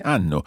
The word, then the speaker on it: hanno: In Italian, we don't pronounce H. – Of course. – It, but it's mute. So instead of hanno, it would be hanno. – Brava, sì, molto bene, hanno.